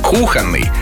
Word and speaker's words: кухонный: Как нужно произносить? Кухонный, плодоносить, таинство, жерло. – Да-да-да. Внезапно. – Жерло и лассо.